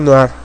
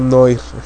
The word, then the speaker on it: noir: Me está corrigiendo por ahí Julio César Montoya, que me dice, se pronuncia noir, no noir. Para mí es arte noir, no.